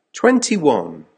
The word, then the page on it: twenty one: American English